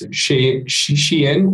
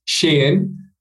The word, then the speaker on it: Shein: It's Shein or Shein, depending on how you want to pronounce it, with the, you know, in Chinese, it's more Shein, but I think a lot of people in the West call it Shein.